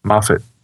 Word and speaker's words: Moffat: Moffat. Okay. Like Muppet.